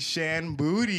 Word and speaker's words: Shan Boody: The one, the only Shan Booty.